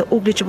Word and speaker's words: Uglich: By the Kremlin, the most ancient place where the Uglich was founded, the Volga River makes a very sharp bend forming a ikone. Ikone in Russian is Ugal. Ugal, Uglich. So we pronounce it Uglich.